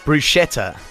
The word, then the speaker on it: bruschetta: Bruschetta.